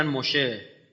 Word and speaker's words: موشه: یکی دیگه از ماشیح های اینا، چون یهودی ها سه و شه تلفظ میکنن، موسی رو میگن موشه، شلمان، سلیمان، شالوم، سلام، اورشلیم، اور یعنی شهر، شلیم یعنی سلامت، دارالسلام.